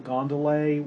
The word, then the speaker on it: gondole: sees a little gondole, which is how the word gondole used to be pronounced all the way to the beginning, at least, of the 19th century in England.